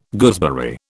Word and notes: gooseberry: British English

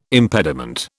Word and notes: impediment: British English

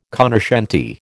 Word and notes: cognoscente: American English

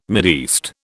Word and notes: Mideast: British English

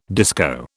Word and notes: disco: British English

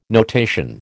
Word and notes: notation: American English

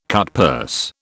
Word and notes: cutpurse: British English

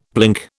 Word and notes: blink: British English

